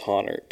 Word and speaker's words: Hahnert: I always say Haynert. Is that right? – It's Honert.